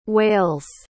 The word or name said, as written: wails